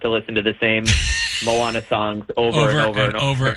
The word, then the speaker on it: Moana: Am I saying that right? I'm out of the kid. I'm out of the kid Disney thing, man. I don't have grandkids yet. My kids are out of the house. I don't watch this stuff now. I have no idea what the hip thing is. – Well, you said it right. It is Moana.